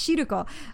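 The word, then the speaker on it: Shiruko (汁粉): Because I was trying to think, is it Shiruko? Am I saying it right? And it is. It's Shiruko for the red bean soup.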